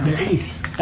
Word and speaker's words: berith: B-E-R-E-T-A. – Well, it's like, it's how you do it. It's B-E-A-R, and I say bear, E-E-T-H, oh, yeah. – Berith. That's how they pronounce it. That's not how it's spelled. It's like a B with a little E, R-I-T.